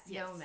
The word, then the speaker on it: Xeomin: Yes, Xeomin. Yes. Okay. So what is the difference? – So they're all neurotoxins in the same drug class. You have Botox, Dysport, Xeomin, then there's some newer ones, Daxxify.